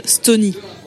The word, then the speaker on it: Stony: Stony, ça s'écrit S-T-O-N-Y, je me rends compte en le disant que ça se trouve, vous comprenez Estonie comme le pays.